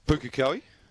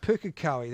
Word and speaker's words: Pukekohe: how do you pronounce that place where Franklin Park is? – Pukekohe. – Pukekohe, there we go.